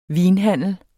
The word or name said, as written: vinhandler